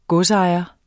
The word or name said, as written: godsejer